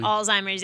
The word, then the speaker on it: Alzheimer's: Did I pronounce that right finally? – Alzheimer's, yep, you got it.